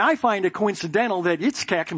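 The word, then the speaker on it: Yitzchak (יצחק): which in the Hebrew is pronounced Yitzchak. That's how you say Isaac, Yitzchak, which, by the way, means laughter.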